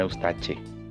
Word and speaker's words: Eustache: Eustaque con CHE, como dicen en italiano, Eustache. Eustache se escribe.